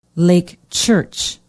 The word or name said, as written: lake church